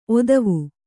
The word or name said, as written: ಓತ್ವ